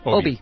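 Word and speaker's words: Obey: Obie. – The Wisconsin Democrat on the show. White House Appropriations Committee Chairman David Obie, the Wisconsin Democrat, who has long championed investment in pandemic preparation.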